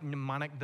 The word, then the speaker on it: mnemonic: Anyone like mnemonic devices? Anybody know what a mnemonic device is? It has a silent M on that word, it's a fun word.